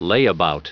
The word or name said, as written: layabout